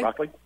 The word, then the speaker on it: Rockly: I believe I'm pronouncing that correctly. – How's it spelled? – I'm sorry? – How is it spelled? – R-O-C-K-L-Y. – Okay. – Rockley? – Okay. I'm going to go that way, too.